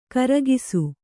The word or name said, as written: ಖರ್ಗಸ